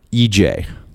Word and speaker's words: EJAE: EJ. – EJ. – That's her artist name. It's spelled E-J-A-E.